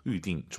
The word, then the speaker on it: 预定: 아, 预定. YU 발음이에요. 위, 위 하지 마시고 다시 한번. – 预定. – 둘 다 사성이에요. 검색해 보세요, 여러분. YU로 시작합니다. 预定.